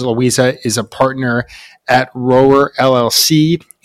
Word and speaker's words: Rower: We pronounce it Roer LLC, but I'll take it.